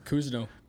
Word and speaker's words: Cousineau: Cousineau.